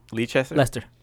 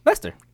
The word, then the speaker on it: Leicester: So the Leicester? – Lester. – Leicester. Do it. – Lester. – It's just Leicester? – Yeah.